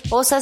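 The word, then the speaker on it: Pawsa: Posa se escribe con Po, W, como si fuera patita de perro, Po.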